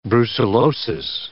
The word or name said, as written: brucellosis